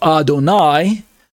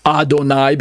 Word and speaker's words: Adonai: On the right, we have Adonai, pronounced Adonai because of the vowel markings, the kamats that I have circled there in red.